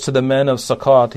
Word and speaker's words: Sukkot: And then he said to the men of Succoth, Succoth is or Sukkot is how you'd pronounce it, I believe, in Hebrew.